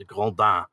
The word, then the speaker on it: Grandin: soul leader, or I uh decided uh, to go like with uh, unlike my pronuncia- pronunciation of Van Gogh, I did try to go for the French, the accurate the Grandin.